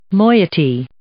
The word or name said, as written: moiety